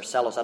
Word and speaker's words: Barcellos: Yeah, in the book I referenced by Richard Barcellos, or Celos, I don't know how to pronounce his name. – Celos. – Huh? – Celos.